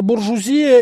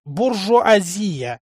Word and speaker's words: буржуазия: Крупная буржуазия и дворянство, господин профессор. – Правильно говорить, буржуазия, господин слушатель.